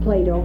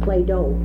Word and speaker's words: Plato: I don't know how many of you know much about Plato, but it's not Plato, it's Plato. And he, but they do sound like, because of the way we pronounce T. We don't say Plato, which we should. We say Plato.